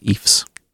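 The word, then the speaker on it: Ephs: In fact, it's pronounced EFs.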